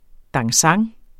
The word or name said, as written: DANSANTS